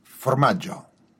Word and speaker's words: formaggio: Formaggio.